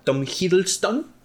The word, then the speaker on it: Tom Hiddleston: Eh, del hermano del Thor, interpretado por el señor Tom Hiddleston,